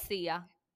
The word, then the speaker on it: Sia: Es Sia.